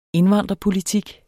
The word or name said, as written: indvandrerpolitik